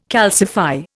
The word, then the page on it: calcify: Indian